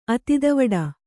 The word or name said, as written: ಅತಿದವಡ